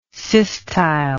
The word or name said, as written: systyle